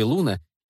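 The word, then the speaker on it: Luna: Она читала заклинание, в паузах которого девушки хором повторяли «Аксилиум Луна». По ударению на первую гласную в слове «Луна» я догадалась, что она читает на латыни.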